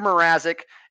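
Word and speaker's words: Mrázek: It's Peter Mrazek, and Mrazek's been nothing Mrazek. Spectacular. Mrazek. Nothing less than spectacular for the Hawks this year. The Hawks are a bad team. Mrazek actually has well over a 900 save percentage.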